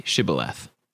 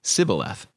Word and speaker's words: Shibboleth: When he said, No, they said to him, Then say Shibboleth. And he said, Sibboleth, for he could not pronounce it right.